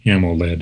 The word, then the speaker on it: AMOLED: So they're just splitting AMO hyphen LED, AMOLED, and pronouncing both components as if they're syllables.